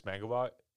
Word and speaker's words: Mangubat: Francis Magubot, M-A-N-G-U-B-A-T.